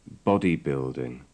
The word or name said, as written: bodybuilding